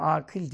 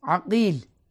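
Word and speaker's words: Akîl: Ama telaffuzu beceremiyor. Akil diyor. Dedim Akil değil. Akil. Yani bu bizim millet. Nerede Akil diyecek? Fatiha'yı zor okuyor. Ama yani doğrusu odur.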